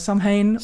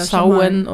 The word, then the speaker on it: Samhain: An alle anderen, und da kenne ich mich nämlich besser aus, Happy Samhain. Oder Samhain. – Oder Samhain. Oder wie auch immer ihr es aussprechen mögt. Alles ist richtig, habe ich gelernt übrigens. Ja, ist alles richtig.